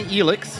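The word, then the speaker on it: Elyx: And so Absolut Elix, I guess I'm pronouncing it wrong. You pronounce it differently than I did. – I say Elix.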